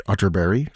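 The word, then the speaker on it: Atterberg: Spelled Otterberg, but pronounced Otterberry, just in case you were Googling.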